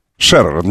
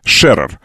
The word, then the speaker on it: Шерер: Шерер, немецкая фамилия, ударение на Е. Первое Е. Шерер.